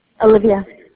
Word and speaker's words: Alivea: Olivia. – Will you spell that for me? – A-L-I-V-E-A.